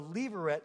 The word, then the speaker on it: levirate: Well, Naomi reckons that Boaz could satisfy what's known as the leveret laws of marriage. Leveret, if you're taking notes, is spelled L-E-V-I-R-A-T-E.